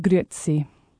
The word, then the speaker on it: Grüezi: with Grüezi. – Grüezi. – Grüezi. And you really pronounce the E in it, so when you read it, the temptation is, because it's spelt G-R-U-E, or umlaut, Z-L-I, is to go Grüezi, but they always really pronounce the E.